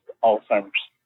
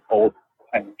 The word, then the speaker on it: Alzheimer's: That's when I learned that it's pronounced Alzheimer's. This entire time, I've been pronouncing it as old-timers.